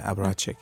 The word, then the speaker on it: Apparatjik: Hvordan er du udtalt? Aparatik? Ja, Aparatik. Ja.